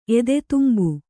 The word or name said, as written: ಎದೆತುಂಬು